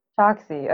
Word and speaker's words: Choksy: Choksy.